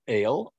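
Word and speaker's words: El (אל): The word God, as you can see off on the right there, means, or it is the word El, pronounced El in Hebrew, and it's a word that means strength.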